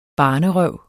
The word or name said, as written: barnerøv